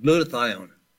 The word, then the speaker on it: glutathione: Now, I remember when I opened up the program, I suggested we would talk about glutathione. It's spelled G-L-U-T, glutathione, T-H-I-O-N-E. G-L-U-T-A-T-H-I-O-N-E.